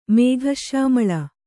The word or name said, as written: ಮೇಘಶ್ಯಾಮಳ